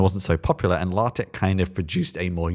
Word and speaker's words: LaTeX: So a lot of people may mispronounce it as LaTeX or LaTeX or whatever, but it's actually LaTeX. And then tex is tech.